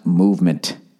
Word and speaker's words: MVMT: MVMT watches, everybody. Pronounced movement. Oh, that's what I'm supposed to say.